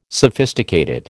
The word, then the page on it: sophisticated: American English